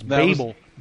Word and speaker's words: Babel: If you mention that movie, you need to say it correctly. It's Babel.